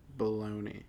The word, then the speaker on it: bologna: Bologna. – Bologna. And you just said B-O-L-A. – B-O-L. It's not E. – It's not. It's O. It's bologna. No, not Bellany or no, Bellani.